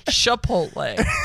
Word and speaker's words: Chipotle: It's pronounced Chipotle. – Okay, short king. Maybe you can't see it from down there, Chipotle.